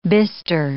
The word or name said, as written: bistre